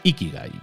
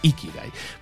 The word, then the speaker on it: Ikigai: Tu iki, no sé si lo digo bien, creo que es Ikigai. No, creo que es con acento en la primera I, digamos, con, inflexión en la primera I. Creo que es tu Ikigai.